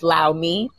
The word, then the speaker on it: LAU_MII: That's Lao Me, pronounced like that.